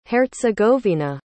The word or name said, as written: hercegovina